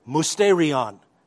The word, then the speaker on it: mysterion: Actually, you would pronounce it mysterion in the New Testament.